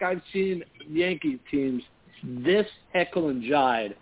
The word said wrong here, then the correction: Jekyll and Hyde: Jekyll and Hyde.